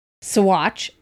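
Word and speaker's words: Saguache: So, Sawatch is how you pronounce it. Sawatch County.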